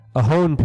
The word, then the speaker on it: Ohlone: the Ohone people. And you will notice, if you look it up, it's spelled O-H-L-O-N-E, but the L is silent, and the reason for that is because it's a Spanish word.